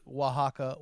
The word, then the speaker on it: Oaxaca: And I go, the white man in the room goes, "I think you meant to say Oaxaca, Oaxaca, Oaxaca, because that would sound like Waka Waka Waka." That's how you pronounce.